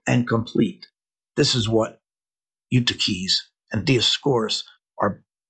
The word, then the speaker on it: Eutyches: Because someone can take that language, in this case, the heretic Eutyches, or for those of you who belabor me about my pronunciation, Eutyches, though don't ask me to say mater theon, it's mater theou, right?